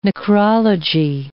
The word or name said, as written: necrology